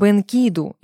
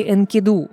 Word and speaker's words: Энкиду: А мы будем говорить о его друге, об Энкиду или Энкиду. Как хотите, ставьте ударение.